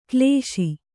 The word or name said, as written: ಕಲುಷ